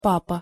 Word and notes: papa: Russian

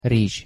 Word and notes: rizs: Hungarian

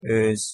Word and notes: oz: Hungarian

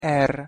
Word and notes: r: Hungarian

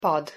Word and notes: pad: Hungarian

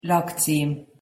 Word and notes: lakcim: Hungarian

